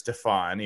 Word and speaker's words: Stephon: Stephon Sullivan, which, by the way, it is Stephon, even though it's spelled like Stephen, to play defensive end.